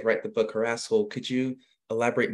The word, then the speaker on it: Harasshole: Harasshole. Am I saying that right, by the way? – You are.